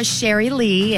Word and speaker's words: Sherry Lee: Now, did I say that right, or is there a French way of saying that? – Sherry, and I'm playing. Now, you said it exactly how you should. Perfect. No notes.